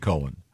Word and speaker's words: Cohen: No, not when it's spelled C-O-H-E-N. – It's definitely Cohen.